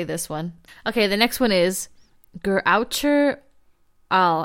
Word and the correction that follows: geräucherter Aal: I probably said that completely wrong. It's like. – I know what you mean. – It said it's the most weird German delicacy food. Yes, how do you say it? – It's geräucherter Aal.